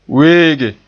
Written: weege

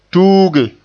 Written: tuuge

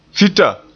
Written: fita

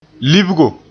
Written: libgo

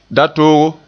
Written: datoogo

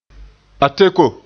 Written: ateko

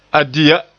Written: ya

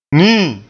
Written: nii

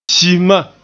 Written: si-ma

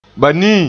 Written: banii

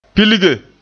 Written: pilge

